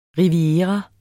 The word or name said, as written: riviera